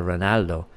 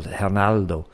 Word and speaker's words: Ronaldo: there is nobody called Ronaldo. In Brazil, the person is called Ronaldo, because the R becomes a HR, a rolling HR.